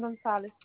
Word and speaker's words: Gonzalez: Carmen Gonzalez. C-A-R-M-E-N. G-O-N-Z-A-L-E-Z.